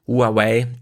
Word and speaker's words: Huawei: Huawei, drei Silben.